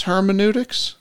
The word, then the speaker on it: hermeneutics: And other folks are like, what is hermeneutics? – Sure. – Am I pronouncing that right? – Yeah.